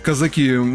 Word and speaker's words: казаки: Ну, вот, кстати, лингвисты российские, тут разные ударения в этом слове «казаки» допускают. Вспомним произведение Льва Николаевича Толстого, но все-таки правилом является на последний слог. Потому именно так ударение я ставлю.